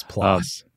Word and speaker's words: PLOS: Indeed, according to, I was looking at a 2019 paper published in PLOS 1 or PLOS 1. I forget which way we're supposed to say it these days. – It's PLOS.